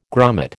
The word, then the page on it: grummet: American English